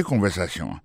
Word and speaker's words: conversation: Coutez conversation. Mot conversation, accent tombé sur avant-dernier syllabe là, you know?